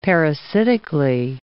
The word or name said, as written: parasitically